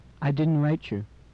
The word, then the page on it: write you: English